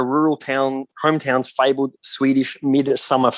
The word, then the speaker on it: Midsommar: And there has been a bit of like debate over whether it's pronounced Midsommar or Midsommar. Apparently, either it works. – Either it's fine, but I see Midsommar because Midsommar is our LGBTIQA plus festival here in Victoria, and I won't be having that happen. – The director pronounces it Midsommar, so I think we should go with that.